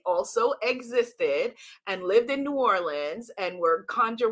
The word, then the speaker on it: New Orleans: Let me get it right, because the New Orleans people come after me. New Orleans. Did I say that right? No, I say it like a Yankee.